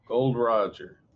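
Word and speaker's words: Gol D. Roger: Rhymes with Dodger. – Gold Roger.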